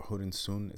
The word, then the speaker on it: Hurensohn: You know, the Bayern players were pissed off at their supporters for bringing up this banner, basically calling the owner of Hoffenheim, the sign said Hurensohn, I think it's, or Hurensohn is how you say it, which basically means son of a whore.